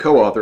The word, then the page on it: coauthor: American English